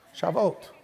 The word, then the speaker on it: Shavuot: This is called the Feast of Shavuot. Oh, see, I thought I said it right the first time. Shavuot.